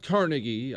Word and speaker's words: Carnegie: It's not Carnegie, I should mention. He's Scottish. It's Carnegie. That's how you say it. Don't shake your head, Chris. It is. I realize I'm not exactly a linguist, but it's Carnegie. Believe me.